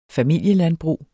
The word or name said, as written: familielandbrug